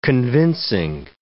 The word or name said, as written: convincing